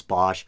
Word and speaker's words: Bosch: I think it might technically, it's supposed to be pronounced Boss, but no one pronounces it that way. I think everyone says Hieronymus Bosch.